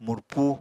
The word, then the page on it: murpu: Apatani